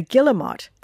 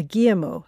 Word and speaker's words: guillemot: And the bird, I'm reliably informed, is called a guillemot and not a guillemot, as I'd said earlier.